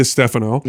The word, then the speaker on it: Stefano: My last name is Di Stefano. – Yeah. – It's D-I space capital S-T-E-F-A-N-O, right?